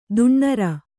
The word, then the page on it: ದಿನಾರು: dināru